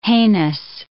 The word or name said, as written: heinous